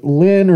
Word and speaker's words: Lenne: spelled L-E-N-N-E, pronounce it Lynn or Lenny. They prefer Lenny, so that's what I'm going to use.